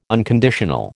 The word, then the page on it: unconditional: American English